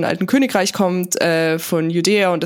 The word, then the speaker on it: Judäa: dass es wirklich Judäa und Samaria heißt.